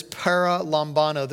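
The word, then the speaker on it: paralambano: The Greek word is para lambano. This is important. I'm going to spell it for you. Para, P-A-R-A. Lambano is L-A-M-B-A-N-O.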